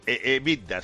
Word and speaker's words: EBITDA: EBITDA, sí.